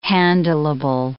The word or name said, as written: handleable